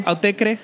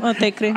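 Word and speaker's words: Autechre: Y la segunda canción que acabamos de escuchar es de Otterker. ¿Así lo pronunciaríamos? – Sí. – Autecre. – Sí, Autecre.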